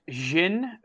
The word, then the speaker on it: Jin: Yeah, so to clear the air, it's Jin, like Jinjiang is how it's pronounced.